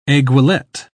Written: aiguillette

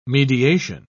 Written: mediation

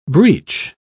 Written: breach

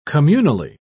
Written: communally

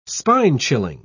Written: spine-chilling

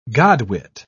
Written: godwit

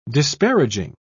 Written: disparaging

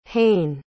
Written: haen

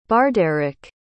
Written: bardaric

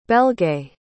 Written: belgae

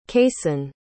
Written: kason

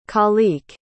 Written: kaliq

